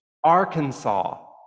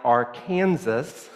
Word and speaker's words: Arkansas: Now, I researched that, and it's not exactly illegal, but there is an 1881 law still in the Arkansas Code that establishes the one true and official pronunciation, Arkansas. And while other pronunciations like Arkansas, while not strictly illegal under this law, are to be strenuously discouraged, there is a rule.